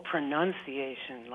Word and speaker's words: pronunciation: Oh, pronunciation. Like nun. – I'd say pronunciation. – The nun, with the nun in there? – Yeah.